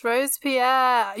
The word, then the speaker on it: Robespierre: For this was the era of Maximilien François-Marie Isidore, I don't know if that's how you say it, de Robespierre. – Yes, Robespierre.